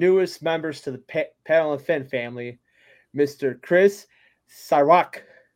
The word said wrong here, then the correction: Slywka: Did I say that right? – No. – Oh, God. – It's Chris Slifka. – Slifka. – Yeah, you're good.